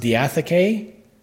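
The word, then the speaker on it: diatheke (διαθήκη): This word covenant here is Strong's number 1242, and it is pronounced diatheke, and it's properly a disposition, i.e.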